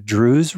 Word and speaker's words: Druze: Is it the Druze religion? – Yeah, is that how you say it? – Yeah.